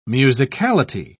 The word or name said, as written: musicality